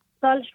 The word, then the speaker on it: Saleh: Salih Abdullah. – Spell it for me, please, because I didn't quite hear it. – S-A-L-E-H.